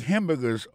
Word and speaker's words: hamburger: N'a pas manqué que dans le mot hamburger, ça quand comme t'as dit, il y a trois syllabes là-dedans. Quand tu appuies et puis plus ça, c'est sous premier syllabe là.